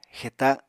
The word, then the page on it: xeta: Apatani